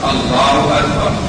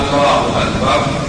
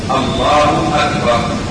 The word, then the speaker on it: الله أكبر: أما هذه التكبيرة فهي ركن من أركان الصلاة، ونطقها الله أكبر، فلو نطقها بمد الهمز الأول فقال الله أكبر لا تصح التكبير، ومن ثم لا تصح الصلاة، لأنه حينئذ يكون استفهام، يكون الهمزة همزة استفهام الله أكبر،